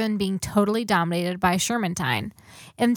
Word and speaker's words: Shermantine: Wesley Howard Shermantine.